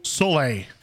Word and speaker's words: Soleil: Soleil. I think that's how you pronounce it. – Soleil? Yeah, like Cirque du Soleil. Which is sun in French.